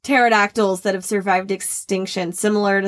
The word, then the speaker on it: pterodactyl: Do you know why you can never hear pterodactyls go to the bathroom? – Gee, why? – Because the pee is silent.